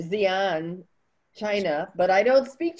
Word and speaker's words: Xi'an: So it's Xi'an. – Xi'an. – Yeah. Xi'an. So I remember a group of Chinese. So it's the owner is from this little village outside. He's from Xi'an and he grew up in this little village that's outside.